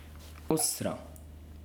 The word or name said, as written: أسرة